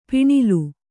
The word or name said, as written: ಪಣ್ಣೆಲೆ